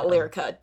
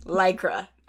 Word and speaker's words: Lycra: It's not Lyrica, dumb, stupid bitch. Lycra. Anyways.